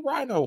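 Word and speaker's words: Rhighno: like R-H-I-N-O. No, it's R-H-I-G-H-N-O. So it's Rhino, like R-H-I-N-O. – I would have done like I would have done like R-E-I-N-O. – Oh, okay, I see that. Yeah. – Yeah. Rhino!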